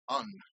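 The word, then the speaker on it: en: And the syllable for low A is written E-N. These, by the way, are all in lowercase. And the pronunciation that I favor is un, but it can also be pronounced n.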